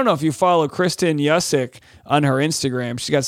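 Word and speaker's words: Juszczyk: I mean, Yusick, it's one of those Polish names. It's J-U-S-Z-C-Z-Y-K. I'm going to say that one more time. That's J-U-S-Z-C-Z-Y-K, and it's pronounced Yusick, I believe.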